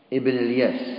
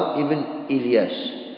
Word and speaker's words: إلياس: قال النووي: وقصي بضم القاف، ولؤي بالهمز وتركه، لؤي ولؤي، وإلياس بهمزة وصل، وقيل بهمزة قطع، يعني يقال ابن الياس أو ابن إلياس، هذه الهمزة قيل أنها همزة قطعية يعني ينطق بها في الوصل وعند البدء، إلياس وابن إلياس، وقيل قطعية وصلية فلا ينطق على بها إلا بها إلا عند البدء، يقال إلياس بن مضار، أما إذا درجت فتقول مدرك بن إلياس ابن الياس ابن مضر.